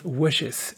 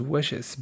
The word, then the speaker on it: wishes: Y luego decimos wishes. Aunque se escribe wishes con una I latina, decimos wishes, best wishes.